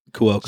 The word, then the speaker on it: Cuoco: Everyone on the show except Kaylee Cuoco or whatever Coco, whatever the fuck. How you say her name? – Cuoco.